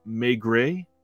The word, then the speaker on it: Maigret: Season one of the Kino release that I just talked about, which my wife texted me, she was watching and said that it's pronounced Maigret. That's the French pronunciation, so that helps.